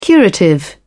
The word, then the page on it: curative: British English